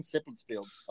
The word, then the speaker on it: Seppeltsfield: Seppeltsfield.